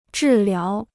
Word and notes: 治疗: zhì liáo

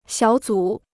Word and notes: 小组: xiǎo zǔ

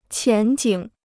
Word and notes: 前景: qián jǐng

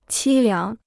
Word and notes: 凄凉: qī liáng

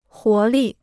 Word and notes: 活力: huó lì

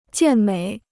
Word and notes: 健美: jiàn měi

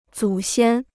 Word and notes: 祖先: zǔ xiān

